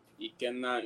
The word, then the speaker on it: Ikenna: So, there's like, there's a few different ways you can say my name, right? So my first name, the Nigerian way to say, the original way how to say it, Ikenna Irebu.